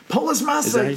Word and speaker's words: Polis Massa: Polis Massa, yes!